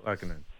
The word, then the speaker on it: Lehkonen: By the way, they gave up a hell of a kid in Barron to get Lehkonen, but this is that how you pronounce his name, Lehkonen? – Yep. Lehkonen. All right, Mac.